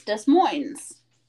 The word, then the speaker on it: Des Moines: Or pronounced in most places, Des Moines.